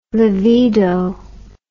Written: livedo

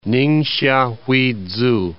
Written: Ningxia Huizu

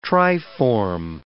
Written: triform

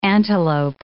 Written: antelope

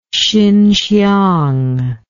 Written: Hsinhsiang

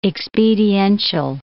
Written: expediential

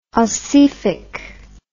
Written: ossific

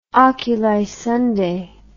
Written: Oculi Sunday

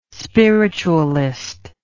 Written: spiritualist

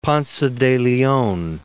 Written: Ponce de Leon

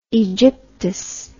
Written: aegyptus

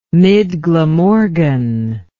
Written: midglamorgan

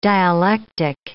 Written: dialectic

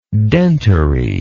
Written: dentary

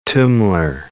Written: tummler

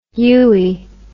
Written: uey

